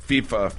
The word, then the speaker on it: FIFA: FIFA, not FIFA? – FIFA, for sure.